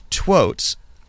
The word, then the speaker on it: twotes: So I spelled that T-W-O-T-E-S. Is that how you would spell it? Twotes? – Yeah, my spell check just turned it into totes. So, but yes, twotes.